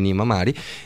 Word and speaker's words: Nima Marie: anche questa versione acustica di alcune canzoni di Nima Marie, devo sempre mettere l'accento finale, e poi naturalmente compararle anche anche, diciamo, e godersele anche nelle versioni da disco.